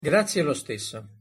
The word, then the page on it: grazie lo stesso: Italian